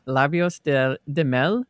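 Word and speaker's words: Lábios de Mel: But, and apologies if I mispronounce it, but Labios de Mel by Tim Maia. – Yeah, really good. Really good pronunciation.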